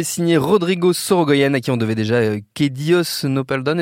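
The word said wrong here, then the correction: Que Dios nos perdone: Est-ce que je le prononce bien, David? – Nos perdone. – Nos perdone. – Non, pas terrible.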